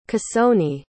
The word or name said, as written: cassoni